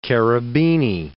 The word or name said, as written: Cherubini